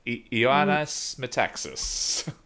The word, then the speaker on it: Ioannis Metaxas: Apparently, on October 28th, 1940, the Greek prime minister at the time, which is, I'm going to butcher this as well, Ioannis Metaxas. – Mm-hmm. – Sounds about right.